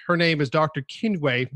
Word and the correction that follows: Chinwe: Did I pronounce that correct? – Yeah, close. Chinwe.